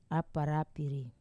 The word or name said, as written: aparapiri